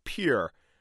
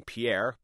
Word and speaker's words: Pierre: In the capital of Pierre, that's how they pronounce it, not like the Frenchman's name Pierre, but rhyming with here, Representative Dale Hargens recounts his vote on the abortion ban.